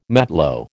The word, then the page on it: matlo: American English